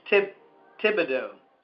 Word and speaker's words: Thibodeaux: And your last name is pronounced Thibodeaux? – Well, it's silent. It's like Thomas. Thibodeaux. – Thibodeaux. – Yeah, you don't say Thomas. – You don't say the Thomas. – H. Okay, so Thibodeaux is how you pronounce it.